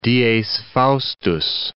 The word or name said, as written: dies faustus